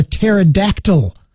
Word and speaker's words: pterodactyl: She wrote, commented with the joke, "Why can't you hear a pterodactyl going to the bathroom?" And the answer is because the P is silent.